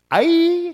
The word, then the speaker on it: Ai: Ai, that's how it's pronounced, it's not Ai, which is near Beth Aven to the east of Bethel, and told them, go up and spy out the region.